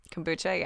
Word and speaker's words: kombucha: Kombucha, yeah.